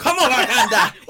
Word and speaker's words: Wakanda: Is that Wakakanda? – Come on, Wakanda. – Wakanda. Yes. I said it wrong.